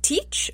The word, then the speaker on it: Teach: So sometimes Blackbeard's name is written and pronounced Teach, like T-E-A-C-H, and other times it's written and pronounced Thatch, like T-H-A-T-C-H, like a thatch roof. I'm going to go against some of the leading experts here who pronounce it Thatch and say, I'm going to say Teach, and I'll tell you why.